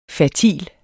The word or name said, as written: fertil